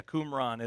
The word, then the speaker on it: Qumran: The Lost Prophecies of Qumran. You wrote that. – Yes. Yeah, Qumran. It's Qumran. I'm sorry. I said it wrong.